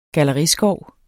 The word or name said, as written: galleriskov